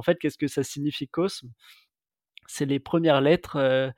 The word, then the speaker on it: Cosme: et c'est vrai que la question du cosme, cosmé ou com même se revient très souvent et notre réponse à ça elle est ben personnaliser votre marque aussi, prononcez-le comme vous le voulez. Finalement, euh, nous, on prononce Cosme,